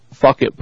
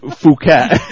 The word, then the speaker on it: Phuket: Simon Brown came back in and said, "I still have tears rolling down my cheeks from laughing at your fuck it pronunciation. It's actually pronounced Phuket."